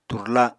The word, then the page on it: turla: Apatani